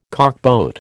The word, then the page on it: cockboat: American English